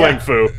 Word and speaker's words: Lang Fu: Lang Fu. It's his prop. it's his, the proper way you would pronounce it in Chinese.